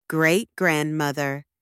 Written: great-grandmother

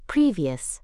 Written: previous

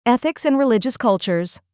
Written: ethics and religious cultures

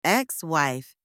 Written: ex-wife

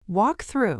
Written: walk through

